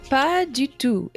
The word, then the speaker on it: pas du tout: Pas du tout.